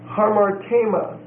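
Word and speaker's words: Harmartema (ἁμάρτημα): The second word is harmartema. Harmartema. H-A-M-A-R-T-E-M-A. It's a long E.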